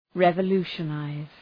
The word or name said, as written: revolutionize